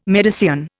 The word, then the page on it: Medicean: Indian